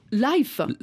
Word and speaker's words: Lhyfe: Life. Comme la vie. – L-H-Y-F-E, hein, pas L-I-F-E. – Je pense que j'ai fait une petite faute d'orthographe, mais ça se prononce life, bon.